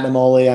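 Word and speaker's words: Naimoli: I mean, I'm on LinkedIn under Matt Namoli. I'm on Instagram under M Namoli. My last name's N-A-I-M-O-L-I, not the easiest.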